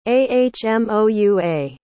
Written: ahmoua

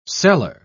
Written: seller

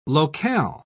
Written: locale

